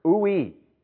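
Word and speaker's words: ui (οὐαί): In the Greek, the word translated woe here is the Greek word ui. That's how it's pronounced.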